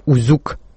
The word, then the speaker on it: Узюк: Всю ночь я смотрел длинный фильм с названием «Узюк», ударение на втором слоге.